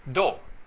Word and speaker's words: Do: La pronunciación japonesa para Dao es Do.